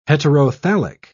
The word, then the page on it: heterothallic: British English